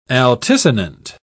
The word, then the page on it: altisonant: British English